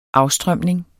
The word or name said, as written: afstrømning